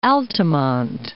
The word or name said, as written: Altamonte Springs